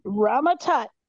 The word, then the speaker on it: Rama Tut: Rama Tut. – Yes, aka. King Tut. – Oh shit, she said the name right.